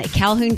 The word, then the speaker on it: Calhoun: Drivers in Calhoun County, did I say that right, Brandon? – Yep.